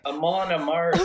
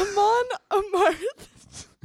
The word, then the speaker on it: Amon Amarth: Amanda Marth? – Is that what you just said? – I said it right that time. – No, yes. – You didn't. – Holy. Amon Amarth. – Amon Amarth. – Good.